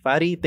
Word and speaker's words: Fady: Well, Fady, thank you so much for taking the time.